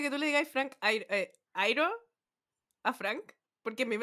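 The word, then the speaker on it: Iero: ¿Airo? ¿Cómo lo dice? – Airo. Frank Airo.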